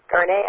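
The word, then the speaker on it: garni: Garnet, I think, is the way it's pronounced. I'm not sure I'm right. Nancy, am I? – I have no idea. – Okay. – How do you spell it? – G-A-R-N-I. I think the I is, I'm sure it's French, and the I takes an A sound, I believe.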